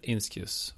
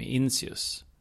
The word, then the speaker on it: Inscius: So I did a bit of research, and it seems that the Romans said Inscius, while during the Middle Ages in Church Latin it became Inscius. I've chosen the Roman pronunciation.